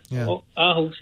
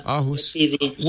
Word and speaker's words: Aarhus: Hey, it's so nice to hear from you in Aarhus. Am I saying it right? – Yeah, that's fine. Yeah. Aarhus, yeah. – Aarhus.